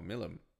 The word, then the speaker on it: Milim: But it's like their characters, like what's the, like I did not, I'm gonna say my least favorite was Demon Lord Malim. – Oh, Milim.